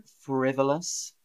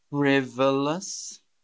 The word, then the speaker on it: frivolous: Andrew, frivolous. Frivolous. Frivolous.